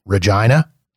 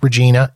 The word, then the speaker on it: Regina: Spend a little bit of time in Winnipeg, then move to, Americans always laugh at the name of this city, Regina. Yes, it's pronounced like that. It is not pronounced Regina. It is pronounced Regina, and it rhymes with what you think it rhymes with.